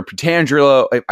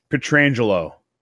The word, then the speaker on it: Pietrangelo: You're like Alexander Pietrangelo. I always say his last name wrong. – Pietrangelo.